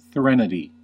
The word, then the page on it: threnody: American English